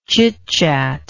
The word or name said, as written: chitchatty